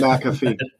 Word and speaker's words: McAfee: Yeah, John McAfee. McAfee is emphasis on the Mac.